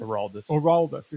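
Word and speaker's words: Aroldis: So, Orlandis, is that how you pronounce it? – Oraldis, excuse me.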